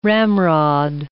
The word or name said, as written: ramrod